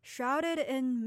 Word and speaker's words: shrouded in: When you're reading the phrase shrouded in, remember to connect the D sound from the word shrouded and the I sound from the word in so that the two words sound connected.